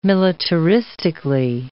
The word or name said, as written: militaristically